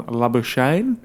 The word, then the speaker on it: Labuschagne: Head and Labuschagne, I can't say that, but how do you say Manus's last name? – There's a couple of ways, but I think we've all landed on Labuschagne.